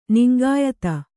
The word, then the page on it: ನಿಂಗಾಯತ: kannada